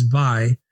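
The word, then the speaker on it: wie: For example, mispronouncing the German word we, which phonetically sounds as vi, but little Phoebe, who is only 5 years old, has perfect German pronunciation.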